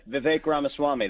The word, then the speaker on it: Vivek Ramaswamy: Help me out with the pronunciation. We tried our best. I'm sorry. – Yeah, you got it pretty good. Vivek Ramaswamy.